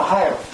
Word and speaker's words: Ahio: Ohio. A-H-I-O. Almost like Ohio, but not just Ohio. Ohio instead of Ohio.